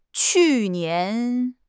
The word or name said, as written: qu4 nian2